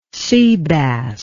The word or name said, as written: seabass